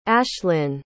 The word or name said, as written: ashlinn